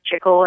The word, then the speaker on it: chicle: And I was very interested in chicle, which is, you know, in English it's chicle, in Spanish it's chicle, which means actually gum in Spanish.